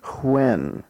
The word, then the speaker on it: when: Now, WH here is pronounced with a slight H sound at the beginning, so we say when rather than when. When would be a simple W, but when we pronounce the WH, we tend to give it a slight H sound at the beginning, so we say when, which, what.